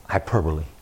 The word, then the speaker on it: hyperbole: That's a hyperbole. Is that the right way to say it? Thank you.